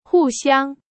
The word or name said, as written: 互相